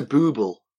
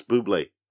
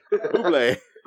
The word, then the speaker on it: Bublé: Is Google similar to Booble? – No, that's Bublé. I think you're mispronouncing it. Yeah. – Michael Bublé. – Yes. Bublé.